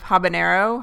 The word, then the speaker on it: habanero: So you're lying and wrong, and I jump in there, but I have to start out by apologizing for Adam's weird try too hard pronunciation of habanero. Habanero is how it's pronounced. – Yeah, I clearly learned it wrong years ago and never did the research to discover that, oh yeah, no tilde on that end.